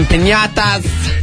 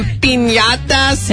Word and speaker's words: piñatas: The DJs, some piñatas. – No, you're not saying that right. – Some piñatas. – There you go.